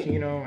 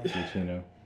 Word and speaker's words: Giacchino: Gino, I don't know. I read his name wrong. – Giacchino.